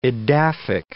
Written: edaphic

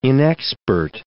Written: inexpert

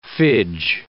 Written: fidge

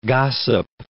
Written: gossip

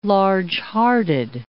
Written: largehearted

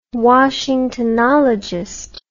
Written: washingtonologist

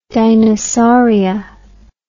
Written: dinosauria

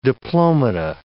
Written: diplomata

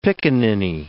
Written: pickaninny

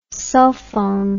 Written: sulphon

